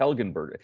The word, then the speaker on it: Helgenberger: No, just Helgenberger. Is the Helgenberger a hard G for the first G in the The second G. There's three Gs to contend with here.